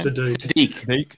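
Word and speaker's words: Saddiq: How do you say his first name? – Sadiq.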